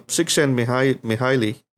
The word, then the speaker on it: Csikszentmihalyi: So Csikszentmihalyi, I had to practice to pronounce that amazing name. and you got it right, by the way.